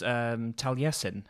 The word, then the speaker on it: Taliesin: And it comes in with, or along with it comes Taliesin, if I'm saying that right. – Yes, yes.